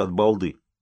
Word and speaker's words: от балды: У нас еще говорят от балды. Запомни это хорошее выражение. Ударение на последний слог, как во французском языке, а звучит по-татарски.